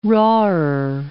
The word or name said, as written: rawer